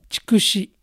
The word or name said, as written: Chikushi